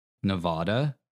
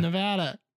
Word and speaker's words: Nevada: Unless one legislator has his way, apparently the only acceptable pronunciation of the state is Nevada, not Nevada.